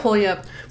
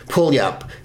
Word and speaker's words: Puyallup: How do you pronounce that? – Pulyup. – Pulyup. It's spelled Puyallup. – Really? – P-U-Y-A-L-L-U-P. Pulyup, Washington.